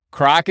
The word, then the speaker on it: craic: It's not like crack cocaine. It's spelled C-R-A-I-C. And crack is basically that beautiful,